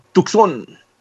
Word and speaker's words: Tucson: That's how they say it on the radio. Tucson.